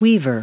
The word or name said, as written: weaver